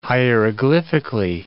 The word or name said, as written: hieroglyphically